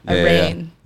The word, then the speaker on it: reign: It's R-E-I-G-N. – Oh, like rain. Reign of terror. – Yeah. – Gotcha. – Rain.